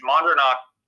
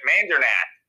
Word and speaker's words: Mandernach: My last name is Mandernach. My grandfather, though, would pronounce it Mandernach.